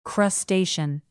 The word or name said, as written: crustacean